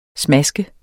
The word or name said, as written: smask